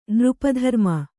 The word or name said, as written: ನೃಪಧರ್ಮ